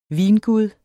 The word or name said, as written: vingud